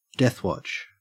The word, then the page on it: deathwatch: Australian English